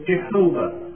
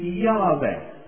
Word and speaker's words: Yahweh: In the Old Testament, it was in the old days, it was sometimes transliterated, interpreted as Jehovah. That's the wrong pronunciation. It is Yahweh.